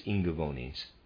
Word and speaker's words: Ingaevones: This came about because the temple priests of this secret mystery center on the Jutland Peninsula decreed that in certain tribes, the Ingavonis, as Tacitus called them, the sexual union of human beings must only take place during the first quarter of the year. Readers aside, I'm pronouncing I-N-G-A-E-V-O-N-E-S as Ingavonis.